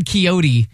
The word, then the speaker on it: Quixote: Oh, Don Quixote. It is a book. I believe, or it's a character, I believe. Don Quixote. – Well, I think it's Don, I don't know. I was told it's Don Quixote.